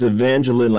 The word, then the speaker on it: Evangeline: It's Evangeline, like Jacqueline.